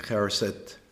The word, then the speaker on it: haroset: And also I've learned that there are quite a few variations, like the Haroset. Haroset? – Yes. – Am I pronouncing that correct?